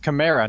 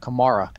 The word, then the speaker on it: Camara: It's Camara, not Kamara. Alvin. It's Camara, like Camaro. Like a Camaro car.